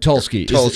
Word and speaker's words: Tulsky: Yeah, I'm sure anybody that in a position that Tursky is in. Is that how you pronounce it? – Eric Tulsky.